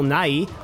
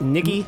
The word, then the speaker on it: Nighy: Bill Nighy. Is that how you say it? Nicky? – No. I think it's Nighy. – Nighy? – I'm almost positive it's Nighy.